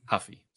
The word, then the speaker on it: Ruffy: So, yeah, Ruffy did look good, but yes, he is not Conor McGregor. Huffy, sorry. – We don't pronounce the R's in Brazil.